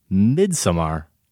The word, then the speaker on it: Midsommar: He says, regarding Josh's pronunciation of the name of that film, it's almost spot on. Just a little bit of emphasis is needed on the first syllable, Midsommar, and it's perfect. – Okay. – So it's not Midsommar, but it's Midsommar.